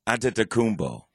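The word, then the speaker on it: Antetokounmpo: Anadokounmpo. – Anadokounmpo? – Anadokounmpo.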